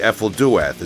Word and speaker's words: Ephel Duath: if I'm pronouncing it right, Ephel Duath. Is that correct? – Yes.